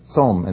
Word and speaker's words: Tsom: It's pronounced the same, Tsom.